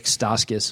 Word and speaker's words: Stauskas: Tim Connelly and company were in Chicago yesterday to take a look at Doug McDermott, Nick Stauskas. Hopefully that's how he pronounces his name. – Yep, that's it.